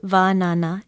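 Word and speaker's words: wānana: Vanana is spelled W-A-N-A-N-A, with a makron or kahako over the first vowel. It is pronounced vanana, and it means prediction, or to predict, to foresee, foretell, or to prophesy.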